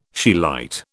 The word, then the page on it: scheelite: British English